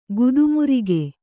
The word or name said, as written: ಗುದುಮರಿಗೆ